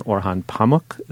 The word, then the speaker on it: Orhan Pamuk: on Orhan Pamuk. Is that how you pronounce that? – I would. the Nobel Prize uh, winner, whose review comes out this Sunday in the New York Times.